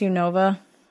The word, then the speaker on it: Unova: It's pronounced Unova.